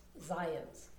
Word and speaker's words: Zajonc: He pronounces it rhymed with science, Zions.